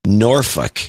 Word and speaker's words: Norfolk: For people out there who want to know, it's pronounced Norfolk.